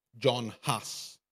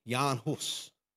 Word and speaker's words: Jan Hus: I'd like to tell you the story of John Huss, as uh, English speakers pronounce it. The Bohemians of his time would have pronounced it Jan Hus.